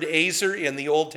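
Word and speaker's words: azer (עזר): It's pronounced azer. Azer as if you've been naughty and the cop has found you. He uses a taser.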